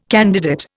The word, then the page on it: candidate: Indian